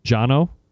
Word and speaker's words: Jono: Jono? – Yeah.